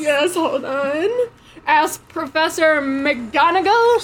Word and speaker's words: McGonagall: Professor McNoggle. – McNoggle. McGonagall, right how it's spelled right.